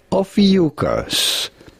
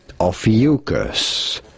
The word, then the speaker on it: Ophiuchus: How do you go about pronouncing it? Ophiuchus. Ophiuchus.